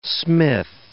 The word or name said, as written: smith